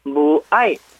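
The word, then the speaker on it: 무아이 (母爱): 우리말 한자 모성애의 성자만 빼고 모에 그다음에 부성어의 성자만 부에 이렇게 갖다가 모성애 부성어를 쓰는데 발음은 무아이 푸아이 무아이 푸아이입니다.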